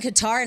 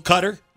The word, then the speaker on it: Qatar: We're saying Qatar, and I heard Jeremy Hubbard say... – Qatar? – Yeah. – I think you can say it both ways. – The announcers were saying Qatar yesterday.